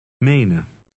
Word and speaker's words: menen: The first E is long, and the EN ending is unstressed. It resembles an sound. Read number 12 aloud. – Menen.